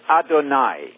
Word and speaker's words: Adonai: There's the word Adonai. You can always remember that because it rhymes with El Shaddai, the Almighty, right?